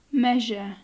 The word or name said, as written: measure